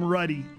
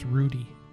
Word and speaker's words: Ruddy: Yep, they called him Ruddy. That's it. That's, Ruddy is short for Rutherford. – Okay. – I would have thought it would have been pronounced Rudy.